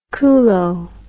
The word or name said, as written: culo